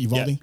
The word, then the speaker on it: Uvalde: Uvalde? – Yep.